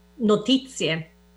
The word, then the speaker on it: notizie: Uh, um, how would you say that uh, in Italian? Notizie.